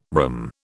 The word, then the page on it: rhumb: British English